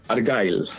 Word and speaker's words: Argyle: La primera, la inglesa, si el título lo digo mal me corriges, Argil o Argille. Se titula así, como se dice? – Argyle. – Argyle, vale.